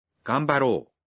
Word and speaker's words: 頑張ろう: You might think it is ga m ba ro u, but it's pronounced ganbaro.